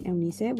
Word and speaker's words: Eunice: And I know people are probably wondering why I say my name two different ways, or probably not. But I have like two versions you can say my name. Usually growing up, my parents always called me Eunice, which is like my, you know, name. But once I got to school, Eunice was very hard, so people just called me Eunice, which is kind of like the English version of my name.